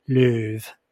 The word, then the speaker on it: Louvre: which he has on loan from the Louvre. I don't even know how to pronounce that place. – The Louvre? I think you got it pretty good. That was Louvre? Nice. That okay. sounded very French.